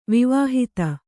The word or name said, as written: ವಿವಾಹಿತ